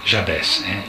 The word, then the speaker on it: Jabès: Si potrebbe pronunciare anche la francese perché è vissuto in Francia, ma a 40 anni è scappato via dall'Egitto, quindi Jabès, il libro dell'ospitalità.